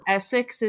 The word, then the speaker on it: Essex: Essex is pronounced the way I would say Essex, E-S-S-E-X.